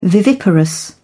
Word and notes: viviparous: British English

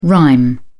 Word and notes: rime: British English

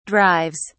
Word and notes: drives: British English

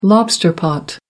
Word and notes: lobster pot: American English

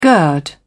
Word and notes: gird: British English

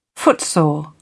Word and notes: footsore: British English

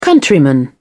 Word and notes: countryman: British English

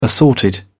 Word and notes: assorted: British English

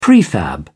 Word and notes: prefab: British English

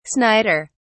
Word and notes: snider: British English

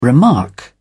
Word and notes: remark: British English

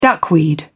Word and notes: duckweed: British English

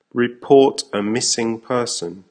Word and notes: report a missing person: American English

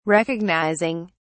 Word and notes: recognizing: British English

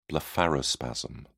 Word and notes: blepharospasm: British English

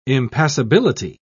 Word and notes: impassibility: British English

